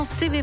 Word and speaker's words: CEWE: L'application CV Photo va vous intéresser. Avec elle, plus besoin de trouver une boîte jaune pour que votre correspondance arrive à bon port. Alors CV Photo, qui s'écrit C-E-W-E plus loin photo,